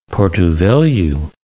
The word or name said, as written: Porto Velho